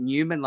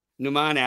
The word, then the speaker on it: Nauman: Is it Newman like in Seinfeld? – No. – Nauman Ali, left-arm orthodox, and Zahid Mahmood, leg spinner.